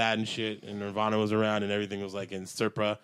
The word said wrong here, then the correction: sepia: Sepia? – Sepia? Well, how do you pronounce sepia? – That? Sepia? – That brownish tone? – Yeah. It's sepia. The brown tone.